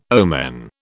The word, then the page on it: omen: British English